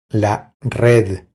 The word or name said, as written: La red